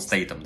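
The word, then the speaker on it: Statham: Надо, кстати, знаешь, научиться говорить правильно Стейтхом. А Стей... не... Он Стейтом, да. да.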